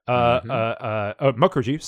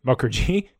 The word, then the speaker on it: Mukherjee: Mukherjee, sorry, apologies. I went back to the old pronunciation that I had in my head that was wrong. Suvik Mukherjee, there we go, mm-hmm. got it right.